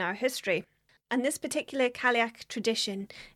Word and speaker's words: Cailleach: We know the Cailleach, or Cailleach as it's pronounced in some parts of Scotland, as an ancient Gaelic environmental deity who built the mountains of Scotland.